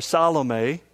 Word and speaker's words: Salome: But that brings me to the third woman in our story, and that is Salome, or Salome, as it's more correctly pronounced.